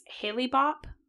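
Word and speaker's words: Hale-Bopp: I'm probably going to mispronounce this. Halley-Bopp? – Yes. – Yes.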